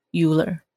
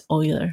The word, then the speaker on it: Euler: 我后来发现它好像好像念作 Euler Hack，Euler，我之前也是以为是 Euler。我也不知道为什么它是发音是 Euler。